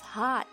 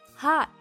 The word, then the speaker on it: hot: Summer is hot. Summer is hot. 注意 hot 美音 ah 的发音是口型相对夸张的 ah。 Hot, 而不是 hot.